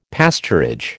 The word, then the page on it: pasturage: American English